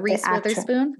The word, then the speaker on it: Reese Witherspoon: And I basically, Reed Witherspoon. I know I'm not pronouncing her name properly. – Yeah, Reese Witherspoon.